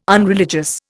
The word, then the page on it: unreligious: Indian